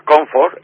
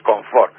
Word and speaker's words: confort: Pues entonces, la palabra que sería comfort, en inglés, pues aquí decimos confort. Ese acento agudo que viene a través, como si estuviese filtrado a través del francés. Por ejemplo, en vez de wagon, decimos vagón, decimos comité, decimos confort, no comfort, ¿vale? – Sí, sí, es verdad.